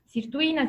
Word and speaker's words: sirtuina: Sirtuina si pronuncia Sirtuina.